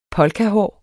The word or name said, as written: polkahår